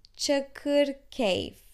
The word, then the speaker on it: çakır keyif: we kind of sometimes pronounce it without the i at the end right before f çakır keyif Chakur Cave Wine glass Sharab kade.